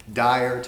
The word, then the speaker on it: Dyer: I grew up in a very small town in Tennessee, Dyer, Tennessee. I like to tell people it's spelled D-Y-E-R, but D-I-R-E works.